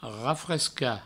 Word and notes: rafrescar: provençal; rafresca

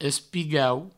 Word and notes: espigau: provençal

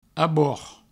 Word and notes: abord: abouord

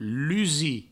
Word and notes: lusir: provençal; lusi

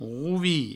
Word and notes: rovilh: rouvi

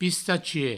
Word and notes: pistachier: pistachie

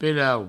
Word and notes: pelau: provencal

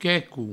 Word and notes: queco: provençal; quecou

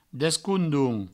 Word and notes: descondons: Provençal; descoundoun

